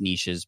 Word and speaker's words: niches: I know it's pronounced niches, but it doesn't rhyme as well.